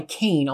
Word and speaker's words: Qayin: And then ultimately, this guy Kane, although it's spelled like Qayin, but it's pronounced the Kane.